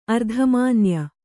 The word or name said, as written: ಅರ್ಧಮಾನ್ಯ